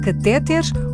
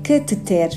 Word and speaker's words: cateter: Qual é a pronúncia correta, catéter ou cateter? a resposta da linguista Sandra Duarte Tavares. A pronúncia correta é cateter.